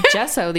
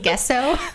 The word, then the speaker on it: gesso: I mean, I said gesso the other day with complete confidence. – You mean gesso? – Gesso, whatever. Damn it, I said it right.